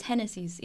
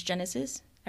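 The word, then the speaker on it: Génesis: So that's part of my story, and that's why my name is Genesis, even though in English it is pronounced as Genesis. I pronounce it in Spanish because that's what giving tribute to where I come from, deciding not to assimilate to American culture and just representing who I am wherever I go, you know?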